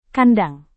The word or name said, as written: kandang